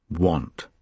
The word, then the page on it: want: British English